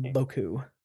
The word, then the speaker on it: beaucoup: That's how you say it. Boku. Okay, anyways. – Is that a real term? – Boku. It looks like it's BEAUCOUP.